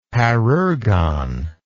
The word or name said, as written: parergon